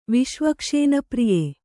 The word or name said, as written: ವಿಷ್ವಕ್ಷೇನಪ್ರಿಯೆ